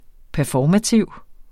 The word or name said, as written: performativ